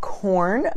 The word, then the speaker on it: Quorn: It's called Korn, but it's spelled Q-U-O-R-N, okay?